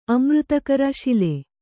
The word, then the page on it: ಅಮೃತಕರ ಶಿಲೆ: amřtakara śile